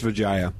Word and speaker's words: Vijaya: Pronunciation check, Generalissimo? – I would say it's Vijaya. – Vijaya. Gaddi, G-A-D-D-E? – That's probably Gaddi, yes.